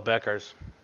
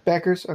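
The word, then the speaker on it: Bueckers: I think it's still Beckers. – Beckers, okay. I I, I was uh, make sure I say that right.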